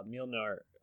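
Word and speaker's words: Mjölnir: By the way, just you know, you brought up Mjolnir.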